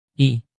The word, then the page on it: i: Indonesian